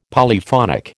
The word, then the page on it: Polyphonic: American English